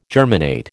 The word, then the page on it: germinate: American English